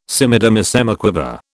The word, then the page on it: semidemisemiquaver: British English